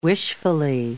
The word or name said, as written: wishfully